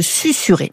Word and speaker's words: susurrer: Clément m'avait écrit pour me dire qu'il n'arrivait pas à accepter l'orthographe du verbe susurrer, dont je rappelle qu'il s'écrit S-U-S-U, deux R, E-R. – S-U-S-U, pour moi, ça se lit Suzu, non?